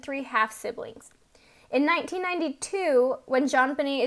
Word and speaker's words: JonBenét: Her name is pronounced in the French style, though, so it sounds like JonBenét. The J at the beginning is a little softer.